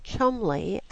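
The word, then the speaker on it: Cholmondeley: The Bolanos name is pronounced Blaino, B-L-A-I-N-O. Why can't these Easterners forget their English heritage with its notorious disdain for spelling? Imagine getting Chumley out of C-H-O-M-O-N-D-E-L-E-Y. It's as bad as Worcester from W-O-R-C-E-S-T-E-R and Gloucester from G-L-O-U-C-E-S-T-E-R.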